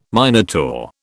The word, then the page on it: Minotaur: British English